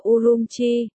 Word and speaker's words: Urumqi: Tiếng Duy Ngô Nhĩ đọc là Urumqi.